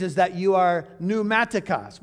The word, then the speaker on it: pneumatikos: It is the Greek word pneumatikos. It's spelled P-N-E-U-M-A-T-I-K-O-S.